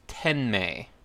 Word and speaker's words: Tenmei: In Japanese, it's pronounced Tenmei.